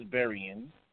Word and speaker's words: Berrian: Am I saying the surname right? – It's Berrian.